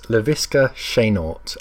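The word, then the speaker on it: Laviska Shenault: So it's Laviska Chenault. Literally, it's spelled like that.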